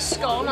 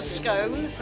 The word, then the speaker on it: scone: It's not just how you eat a scone, whatever, it's how you say it. – It's definitely a scone, not a scone.